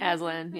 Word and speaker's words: Aslan: And they find out there's this kind of, like, god creature in this world called Aslan? – Is that Aslan, it? Yes.